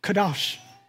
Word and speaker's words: kadosh: The Hebrew word holy, I'm going to butcher this one more than usual, but kadosh.